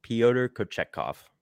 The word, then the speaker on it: Pyotr Kochetkov: Pyotr Kochetkov.